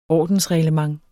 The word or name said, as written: ordensreglement